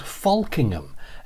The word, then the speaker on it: Folkingham: F-O-L-K-I-N-G-H-A-M. Very nice little village. And the reason I went was the locals pronounce it slightly differently. And to be honest, it sounds like they're swearing. So the L is silent and the vowel sounds more, well, it doesn't sound like an O if you know what I mean.